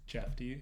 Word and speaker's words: Jefte: My name's Jeff T. – By the way, Jeff T is not an initial. It's spelled J-E-F-T-E. It's a name.